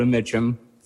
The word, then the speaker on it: Mitchem: My name is Dakota Mitchum. For the record, that's spelled M-I-T-C-H-E-M, and I'm the mayor of Joliet.